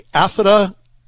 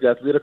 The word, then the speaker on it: Athlita: The founder of Athlita, I want to say this right, Athlita Comics. Is that better? – Yeah, it's perfect. Athlita Comics, that's it.